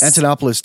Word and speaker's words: Antonopoulos: Antonopoulos did one time at the Texas Bitcoin conference.